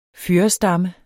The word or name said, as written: fyrrestamme